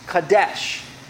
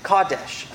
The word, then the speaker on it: kadesh: The word holy here in this text is kadesh. Kadesh. Emphasis is on the ka. Kadesh.